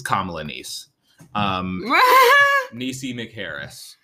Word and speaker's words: Kamala: Kamala Harris's niece has like some kind of company, and she's just like kind of pumping out Kamala merch.